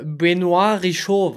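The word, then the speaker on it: Benoît Richaud: Ich habe gerade im Kopf, sagt dir Benoit Richaud was? – Ja, ich habe ihn richtig ausgesprochen.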